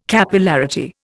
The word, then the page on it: Capillarity: Indian